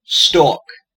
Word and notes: stalk: American English

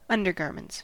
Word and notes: undergarments: American English

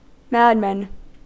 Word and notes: madman: American English

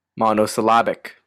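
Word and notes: monosyllabic: Canadian

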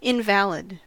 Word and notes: invalid: American English

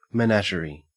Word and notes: menagerie: Australian English

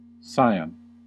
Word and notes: scion: American English

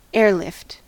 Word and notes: airlift: American English

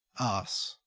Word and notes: arse: Australian English

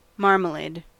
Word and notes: marmalade: American English

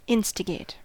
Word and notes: instigate: American English

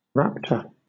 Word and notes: raptor: British English

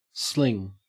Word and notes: sling: Australian English

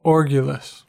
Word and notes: orgulous: American English

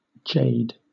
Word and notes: jade: British English

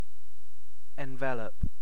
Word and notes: envelop: British English

